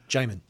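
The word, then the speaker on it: Djimon: Digimon Hatsu is in it. – Oh, thank you. Jaimon Hatsu. – Huh? – Jaimon. – Yeah, yeah, what I said. The D is silent.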